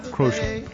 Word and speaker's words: Crozier: We're going to talk about the website Crown and Crozier. Did I say that right? – Yeah, his podcast. – Podcast. It comes with the website, too. Right. – Crozier. – Crozier. I knew it.